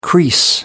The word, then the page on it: crease: American English